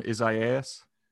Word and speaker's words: Isaias: Our most recent incident was Tropical Storm, I'll try to pronounce this, Tropical Storm Isaias. – I think that's right. – Yeah. – Very good. – Nailed it. – Nailed it.